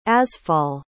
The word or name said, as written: azfal